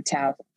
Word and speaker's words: Tao: And it's and she goes for the peacefulness of the Tao Te Ching, you guys. – Yes. I don't know what that is, but it sounds authentic. – That's what it's the T is pronounced as a D.